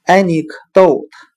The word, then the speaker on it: anecdote: 我们用谐音法来记忆这个单词 anecdote，它的发音很像汉语的“ 爱你可逗他”。